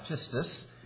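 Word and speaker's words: pistis (πίστις): And the Greek word is pronounced pistis.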